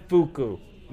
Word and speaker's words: Fuku: It's spelled F-U-K-U. How'd they get that on? – Yeah. – How do they call it? Because no one pronounces it Fuku. – No, okay. Asian people pronounce it Fuku.